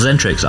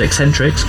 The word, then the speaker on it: Xentrix: Because he said they're called Zentrix. I was never sure if it was Eccentrix, because it's spelled X-E-N-T-R-I-X, whether it was Eccentrix or Zentrix, but it is Zentrix.